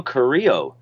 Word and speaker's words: Carrillo: Next up, you had Universal Champion Seth Rollins pick up the victory over Humberto Carrillo. – Oh, wow. You pronounced it right this time.